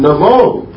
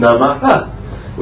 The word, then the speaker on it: Namo: But when Aha is the last syllable and Ba is the first syllable of the next word, then Aha becomes O. Therefore we say Om Namo. We don't say Om Namaha.